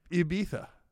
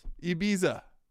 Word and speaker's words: Ibiza: But he's pronouncing the I like in American. But it's you got to pronounce the I like in Spanish, which is E. – Ibiza. – There it is. – Ibiza.